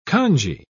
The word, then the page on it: congee: British English